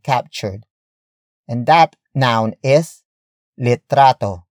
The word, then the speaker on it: litrato: And that is litrato. Litrato. Litrato. Litrato is spelled as L-I-T-R-A-T-O.